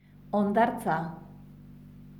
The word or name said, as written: hondartza